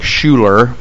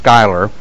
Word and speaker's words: Schuyler: And then I have a brother named Skyler, and it's spelled S-C-H-U-Y-L-E-R. It looks like Schuler, but it's pronounced Skyler.